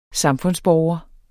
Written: SAMFUNDSBORGERNE

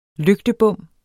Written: lygtebom

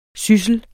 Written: syssel

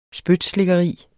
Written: spytslikkeri

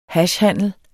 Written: hashhandel

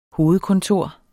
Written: hovedkontor

